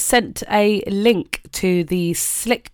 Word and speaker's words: Slique: Slick is spelled S-L-I-Q-U-E. You might have heard me saying Sleek J Adams. It's not Sleek, it's Slick. I have it on good authority.